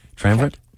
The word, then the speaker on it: triumvirate: And it's, I mean, it really is a triumphant. Did I say it right? I think triumvirate? – Yeah.